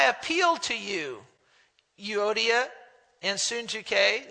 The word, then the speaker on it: Syntyche: There was two ladies, Euodia and Syntyche, is how you say her name, Syntyche.